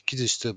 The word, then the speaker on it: 喜寿司: 中央区人形町の木寿司。はい。というわけで、今回は人形町の寿司屋さん、木寿司さんをご紹介したいと思います。で、この木寿司さん、あの、喜ぶお寿司と書いて、木寿司と呼ぶんですけれども、もともとあの、関数字の 7 という字を 3 つ重ねて、木寿司と呼んでいたそうです。